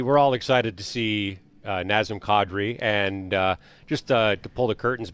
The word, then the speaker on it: Nazem Kadri: Nazem Kadri is how it's supposed to be pronounced.